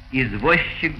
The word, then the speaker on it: извозчик: Группы ЗЧ, СЧ произносятся как ща. Извозчик, грузчик, счастье, подписчик, брусчатка.